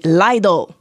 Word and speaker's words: Lidl: Et donc, il y avait deux prononciations. Ils ont quasiment fait un point de prête pour dire, OK, la gang, c'est Lidl.